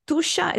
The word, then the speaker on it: Tuscia: You pronounced it perfectly in the introduction. Tuscia is the name of a territory roughly between Rome and Florence.